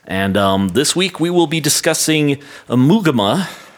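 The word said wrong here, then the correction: Ummagumma: by Amugama? Is that how it's pronounced? – Amugama. – Amugama. Amugama by Pink Floyd. – Amugama. – Umaguma. – Umaguma.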